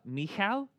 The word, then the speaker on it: Michal: And so he goes home to his wife, Saul's daughter, which in Hebrew is Michal, is how you pronounce it.